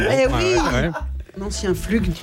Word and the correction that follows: flic: On ne dit pas flug, on dit un flic.